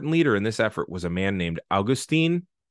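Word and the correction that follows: Agustín: Uh, I'm sorry, Agustin uh, Farabundo Martí Rodriguez, or Farabundo Martí for short, or Martí.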